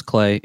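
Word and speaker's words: Klei: Nope, yep, it's Clay. It rhymes with play.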